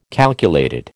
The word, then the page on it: calculated: American English